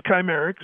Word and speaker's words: Chimerix: Chimerix, is that how it's pronounced? – It is Chimerix.